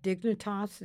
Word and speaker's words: Dignitas: You find this organization in Switzerland called Dignitas, is that the right pronunciation? – Yeah.